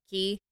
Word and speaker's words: Qi: Like, some people call it chi and some people call it ki.